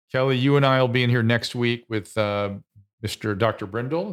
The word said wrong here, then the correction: Bridle: Byron Bridle, yes. – Wish we could get Kelly back on Twitter. – Bridle.